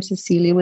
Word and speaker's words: Cecilia: I think you have to spell it somewhere because it's spelled in, I am Cecilia with an E, not an A.